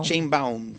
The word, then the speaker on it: Sheinbaum: La señora Claudia Sheinbaum, lo dije bien. – Sheinbaum.